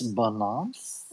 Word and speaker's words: Binance: I think it's pronounced Binance. – It's Binance.